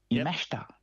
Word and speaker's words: Yamashita: Yamashita, as I said it properly, who played so well over here in Australia, and she won the British Open.